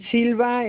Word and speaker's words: Silva: The way G-L-O-R-I-A. Yes. – Okay, and your last name? – Riz Silva. Riz, R-U-I-Z. And Silva is S-I-L-V-A. – All right. So I have Gloria, G-L-O-R-I-A, Ruiz, R-U-I-Z, Silva, S-I-L-V-A.